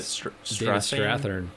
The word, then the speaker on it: Strathairn: That guy, David Strathairn. Is that how you say it? – Strathairn.